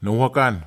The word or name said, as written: nawhakan